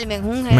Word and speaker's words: menjunje: ese que merequetengue, ese mejungue. – Ah, el menjunje. – Mejunje. El menjunje. No, digo yo. Ah, no, el menjunje he escuchado yo.